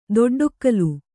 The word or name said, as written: ದೊಡ್ಡೊಕ್ಕಲು